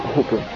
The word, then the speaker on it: Hopper: Alors, Marcus prend souvent la voiture appelée Grasshopper, car en anglais le H se prononce, Hopper.